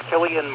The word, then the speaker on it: Cillian: Okay, his name is kind of difficult to pronounce correctly, but it's Cillian Murphy.